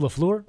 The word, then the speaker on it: LaFleur: I'm sorry, but he ain't changing his last name to LaFraud. yeah, it's pronounced LaFleur.